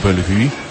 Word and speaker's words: Bellevue: Belweder, czyli Schloss Bellevue. Niemcy tutaj to tak podkreślają, tą francuską wymowę, ponieważ łączą to również z wielką historią, możliwą historią tego miejsca.